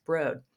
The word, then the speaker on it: Broad: Okay, so the first thing that you should know is that the word is written B-R-O-A-D and pronounced Broad. So anytime you see it,